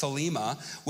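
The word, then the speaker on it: thelema (θέλημα): So, we're, you know, um, the Greek word for will is pronounced thelema, which means God's desire, God's intent, God's purpose, and God's wish.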